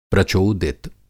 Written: प्रचोदित

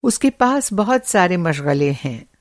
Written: मशग़ला

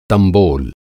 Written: तंबोल

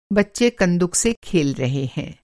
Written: कंदुक

